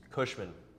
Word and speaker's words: Cushman: Cushman.